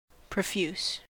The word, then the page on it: profuse: American English